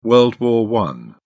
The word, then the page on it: world war i: British English